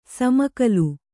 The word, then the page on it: ಸಮಕಲು: samakalu